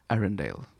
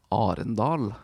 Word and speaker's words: Arendal: How do they pronounce it in the American English version? – Arendelle, I think. – And it's actually... – Arendal. – Yep, there you go.